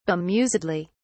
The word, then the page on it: bemusedly: British English